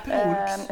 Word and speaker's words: Proulx: jeg, jeg aner ikke hvordan det uttales. Det er P-R-O-U-L-X, tror jeg det skrives. Jeg er helt sikker på at det er Prooks.